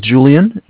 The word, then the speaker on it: Julian: My name is Julian. It's J-U-L-I-A-N. – Mhm. – And I'm calling from Bank of Cardiff. It's spelled C-A-R.